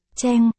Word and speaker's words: Cheng: Đây là bính âm, quốc gia của tôi hay dùng, đọc là. Cheng, là họ của một người.